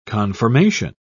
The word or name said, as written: conformation